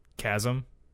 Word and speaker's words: chasm: How do you pronounce C-H-A-S-M? – Chasm? – Chasm, okay.